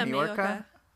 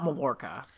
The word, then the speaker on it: Majorca: Yeah, Majorca. – It's not Mallorca.